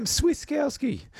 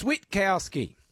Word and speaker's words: Switkowski: Sam Switkowski. You East Coast clowns, it's Switkowski.